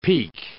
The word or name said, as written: peek